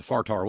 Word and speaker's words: fire tower: And so the place where the Fartar was is Fartar Mountain. Fartar. F-I-R-E-T-O-W-E-R, pronounced Fartar.